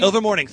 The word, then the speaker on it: Ilvermorny: So the school is Ilvermorny? – Ilvermorny, thank you.